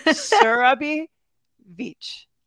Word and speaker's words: Surabhi Veitch: SURABHI VEITCH.